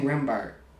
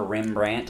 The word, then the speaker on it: Rembrandt: Wait, Rembrandt. I've been saying it right then. – No, you've been saying Rembart.